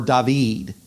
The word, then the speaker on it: David: And, uh, and then the other three churches are over in the vicinity around the city David, or David, as it's pronounced in Spanish.